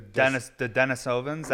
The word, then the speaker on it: Denisovans: The Denisovans. That's how I've heard it pronounced. So that is the way it's pronounced.